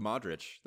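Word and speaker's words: Modrić: No, the name of the player that I just butchered his name, Mudrick. It reminds me of I just call him Luka Modric. That's what I just call him. – Modric. Okay, yeah, that works for me.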